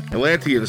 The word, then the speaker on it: Atlantean: The Atlantine sword from Conan Barbarian. I'm pronouncing that wrong. Atlantean sword.